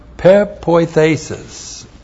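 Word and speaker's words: pepoithesis (πεποίθησις): And then we have pepoithesis. Looks like that in the Greek. That's P-E-P-O-I-T-H, long E-S-I-S.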